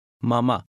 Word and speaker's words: Mama: Mama. – Repeat it aloud after him. Always repeat aloud after the speaker. – Mama. – The Turkish vowel A is usually pronounced as the A in father.